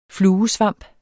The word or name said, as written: fluesvamp